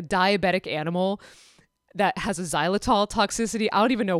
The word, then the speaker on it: xylitol: I know it sounds like a Z, xylitol, but xylitol starts with an X.